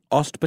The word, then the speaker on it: Ost: Ost Battalion.